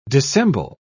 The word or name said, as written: dissemble